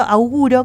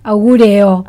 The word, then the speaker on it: auguro: Bueno, yo augurio, auguro, ¿cómo se dice? – Augureo.